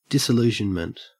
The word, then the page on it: disillusionment: Australian English